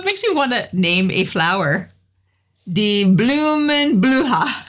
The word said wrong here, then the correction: blühen: Blühen. – Blühen.